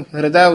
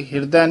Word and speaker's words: हृदय: इसनु हृदय उच्चारण करना, हाहा रारे नु सियारी ला के हृदय नहीं बोलना, हृदय।